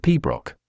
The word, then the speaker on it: pibroch: Piabarichad, pronounced pibroch, is a classical music genre for the Great Highland Bagpipe that involves playing complex and melodic tunes with a slow tempo.